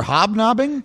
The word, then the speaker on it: hobnobbing: What word are you looking for? Were you looking for hobnobbing? – Correct. Yes. That's what he's trying to say.